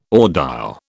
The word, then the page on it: audile: British English